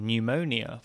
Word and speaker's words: pneumonia: Think of tsunami from Japanese with the silent T, or pneumonia from ancient Greek with the silent P.